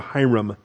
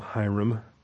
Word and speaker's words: Hiram: So, we get introduced here to Hiram, or Hiram. I call him Hiram. I guess the Hebrew pronunciation.